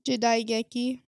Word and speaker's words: Jidaigeki: Jidaigeki.